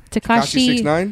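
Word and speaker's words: Tekashi: Takashi 6ix9ine.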